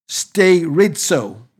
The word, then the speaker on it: sterizo: In the Greek, the word establish is stairizo. It's spelled S-T-E-R-I-Z-O. Stairizo.